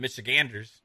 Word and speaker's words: Michiganders: I was going to say Michiganders, but Michiganders, I guess is how you properly pronounce it.